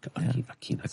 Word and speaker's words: Aquinas: Well, speaking of religion and Christianity and Catholicism, I was going to bring up Mr. Thomas Aquinas. – Okay. – Did I say his name right? Aquinas.